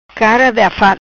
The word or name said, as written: Cara de afat